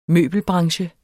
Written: møbelbranche

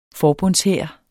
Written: forbundshær

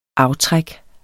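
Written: AFTRÆK

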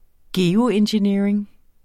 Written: geoengineering

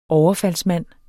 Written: overfaldsmand

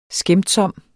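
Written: skæmtsomst

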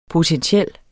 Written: potentiel

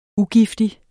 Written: UGIFTIG

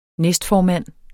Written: næstformand

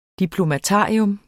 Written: diplomatarium

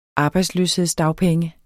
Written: arbejdsløshedsdagpenge

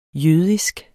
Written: jødisk

